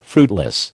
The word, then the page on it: fruitless: American English